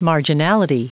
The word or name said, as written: marginality